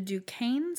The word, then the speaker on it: Duquesne: The Duquesne Spy Ring. – Okay. – It's spelled like the university in Pittsburgh. Duquesne.